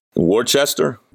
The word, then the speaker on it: Worcester: Worcester. Come on, Al. Even I'm not from New England. Even I know how they say it there.